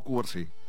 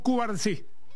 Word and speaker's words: Cubarsí: Habla Pau Cubars. ¿Cómo es que se pronuncia? – Pau Cubarsí. – Yes. Pau Cubarsí.